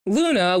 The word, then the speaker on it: Glüna: Luna, which is spelled as Glüna or Glüna, but it's a silent G and we cannot pronounce the umlaut.